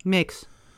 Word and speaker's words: Max: HBO eh, Max, Max, hoe spreek je het uit? Max, toch? – Max. Ja, we pakken het gewoon op zijn Engels.